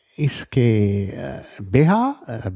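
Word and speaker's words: uisge beatha: Je nach Ort, Ischgë oder so ausgesprochen. Also ist immer die Frage, ist das schottisches Gälisch oder irisches Gälisch, also sind die Aussprache ist etwas unterschiedlich. Ischgë, Ischgë, Ischgë und Ischgë eigentlich auch mit palatalisiertem K und das ist heißt Wasser, aber der Ausdruck ist eigentlich vollständig Ischgë Beha, Beha, also Wasser des Lebens, Beha heißt das Leben.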